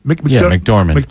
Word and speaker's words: McDormand: McDormand, is that how you say her name? – McDormand.